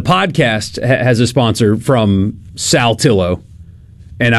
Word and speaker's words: Saltillo: That's Saltillo. People screw that one up all the time. – I get corrected. – Salt. They call it Saltillo. They add that T in there somehow that makes it weird.